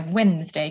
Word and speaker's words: Wednesday: But so he's the Allfather, who in Germanic myth is called Woden, which is where Wednesday comes from. That's where the D comes from that nobody pronounces.